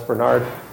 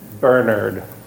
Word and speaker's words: Bernard: Everyone who I read some stuff, I watched some videos, every British person pronounced it Bernard, not Bernard. I'm not British, though, so we're going to go with Bernard today.